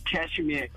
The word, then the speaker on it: Kashmir: It's Kashmir. K-A-S-H-M-I-R. – That is? Correct. – Kashmir. – You're off the starting blocks. It's Kashmir, as they would call it, that Kashmir region.